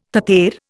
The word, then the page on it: tater: Indian